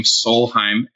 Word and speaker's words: Solheim: Yeah, I have a website, just my first name, Bruce, middle name, Olav, that's spelled O-L-A-V, last name, Solheim, S-O-L-H-E-I-M, as in Michael.